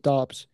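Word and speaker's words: Doubs: I noticed Romeo uh, Dubs, Dubs, what's his last name? – Uh, I think they pronounce it Dobbs.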